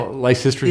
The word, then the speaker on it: Lysistrata: Lysistrata, or how, I don't know how you pronounce the, this. – Yeah, yeah. Lysistrata, yeah.